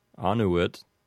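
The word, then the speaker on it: Annuit: I believe that it's pronounced this way because the last two syllables in the word anuit are both short, which would put the accent on the first syllable of the word. So that would be anuit.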